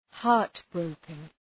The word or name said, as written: heartbroken